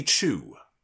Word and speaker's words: Choo: and instead her new name will be Wendy Chu. As in C-H-O-O, that's how it's spelled, like Choo Choo Train.